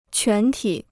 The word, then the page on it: 全体: quán tǐ